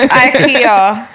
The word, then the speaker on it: IKEA: No, but it's IKEA. – IKEA. – What do you mean IKEA? Where does this come from? – That's how you pronounce it. It's a Swedish company. – Their Christmas ad. – Yeah, it's IKEA, just like Nike.